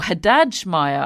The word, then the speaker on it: Haddad Maia: But he predicted Beatrice Haddad Maia, or Haddad Maia, I believe we should be calling her. I believe that's the correct pronunciation.